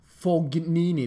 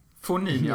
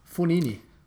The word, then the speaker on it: Fognini: Fognini, det är alltså fel uttal. – Japp. Fonini alltså? Fonini. Exakt. En språkkurs.